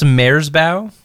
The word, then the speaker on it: Merzbow: It's pronounced Merzbow, actually. – Merzbow? – Yeah, Merzbow.